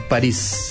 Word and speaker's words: París: ¿París o París? – París. – París, con acento en la I.